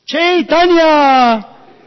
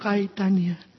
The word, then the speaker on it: Chaitanya: told me how to pronounce Chaitanya and not Caitanya.